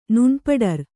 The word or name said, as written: ನುಣ್ಪಡರ್